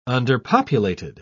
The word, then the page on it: underpopulated: British English